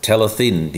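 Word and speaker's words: Talyllyn: In fact, did I pronounce it right? – Talythin, you did.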